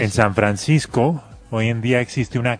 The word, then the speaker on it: San Francisco: O como dicen, hombre, los niños, en Sanforisco, porque no la pueden pronunciar.